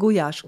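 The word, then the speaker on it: gulyás: Ist, also, Gulasch, also es wird anders ausgesprochen, Gujasch.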